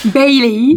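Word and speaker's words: Bayley: non, mais parce que elle a dit Bélé, moi j'ai pensé à la boisson alcoolisée en fait. C'est la manière dont tu l'as prononcé. Je me dis comment ça Bélé, n'importe quoi. – Bailey. – Bailey, voilà Bailey. Bianca Belair.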